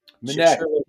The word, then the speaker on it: Minnect: But you introduced me to this, which you do a lot of stuff called, is it Minnect? – Minnect, yeah. Minnect. Like connect with... – Ah, I put the emphasis on the wrong syllable.